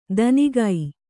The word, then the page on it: ದನಿಗೈ: danigai